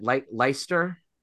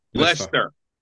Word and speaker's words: Leicester: Around the shutter speed, you're going to have to pronounce it Leicester. – Leicester.